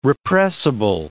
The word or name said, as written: repressible